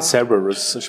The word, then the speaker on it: Cerberus: Cerberus, spricht man es aus? – Genau, ja, Cerberus.